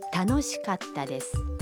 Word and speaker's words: 楽しかった: 下面请大家跟着录音来练习一下发音，请注意カッタ的发音，要在カ和タ中间停顿一拍。 – 楽しかったです。